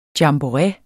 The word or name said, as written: jamboree